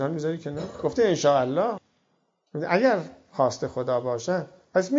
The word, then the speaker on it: انشاءالله: یعنی این انشاء اللهی که میگوییم، اولا درست بگیم تلفظشو، انشاءالله نگیم یا ایشالله نگیم. انشاءالله سه کلمه است، ان و شاء و الله، یعنی اگر خواست خدا باشد.